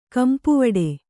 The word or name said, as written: ಗುಂಪುವಡೆ